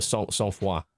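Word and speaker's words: Sang-Froid: Uh, and I kept mispronouncing the name of it. I called it Sang Freud. No, Sang Froid, apparently.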